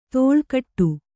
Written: ತೋಳ್ಗಟ್ಟು

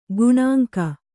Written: ಗೇಣಂಕ

